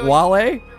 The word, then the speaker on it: Wale: We will take your calls on all things Jalen Brown and Whale right after this. Nice. It is 937 WEEI. Is this Ken's favorite rapper, Whale? – One and only. – Otherwise known as the properly pronounced Wale.